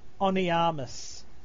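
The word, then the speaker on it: Honnêamise: Wings of Onyamas. – The H is not pronounced? – The H is not pronounced, yes.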